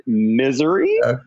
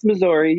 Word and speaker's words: Missouri: Some people might pronounce it Missouri, but it's Missouri.